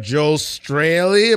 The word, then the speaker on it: Joel Straley: And we have Joel Straley.